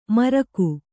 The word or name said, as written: ಮರ್ಗು